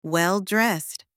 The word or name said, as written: well-dressed